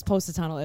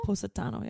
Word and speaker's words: Positano: Okay, I've been there and I called it Positano. Am I doing it wrong? – Yes. – Positano. – Positano. Positano. – How do you say it? – Positano. – It's Positano? – Positano, yeah. – Not Positano. Yeah, that sounds so wrong now that it's coming out of my mouth.